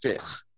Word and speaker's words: fiqh: And I'm like, first of all, it's not Sharia law. It's fiqh, okay, which is spelled F-I-Q-H, and that's Islamic jurisprudence.